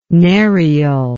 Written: narial

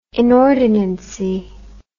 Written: inordinacy

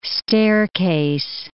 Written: staircase

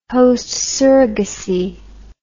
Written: host surrogacy

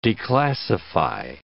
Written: declassify

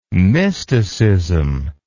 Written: mysticism

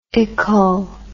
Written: ical

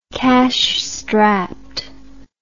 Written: cashstrapped

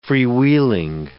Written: freewheeling

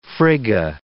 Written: Frigga

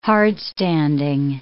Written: hardstanding

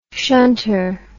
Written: shunter

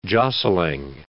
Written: jostling

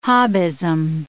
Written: Hobbism

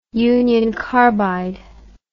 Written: union carbide

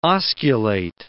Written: osculate

